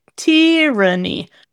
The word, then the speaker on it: tyranny: But with tyranny, for some weird reason, we emphasize the first syllable.